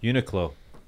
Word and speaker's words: Uniqlo: Uniqlo. $30. – Uniqlo? Oh, is that how you pronounce that? I always wondered.